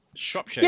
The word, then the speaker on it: Shropshire: Am I saying it right? Shropshire? – Shropshire. – Yeah. Shropshire. Close enough.